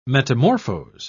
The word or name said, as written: metamorphose